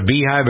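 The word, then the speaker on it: Beyhive: It's called the Beehive, but it's spelled B-E-Y. – Yeah, like Beyonce. Okay.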